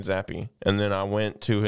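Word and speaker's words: Zappe: But you do know it's it's actually pronounced Zappy, right? Zappy. It's actually pronounced Zappy. I always thought it was Zapp.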